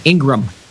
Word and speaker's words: Ingraham: Laura Ingraham, and her name is spelled like Graham Cracker, Ingraham, but it's pronounced just like Graham Cracker, Ingraham.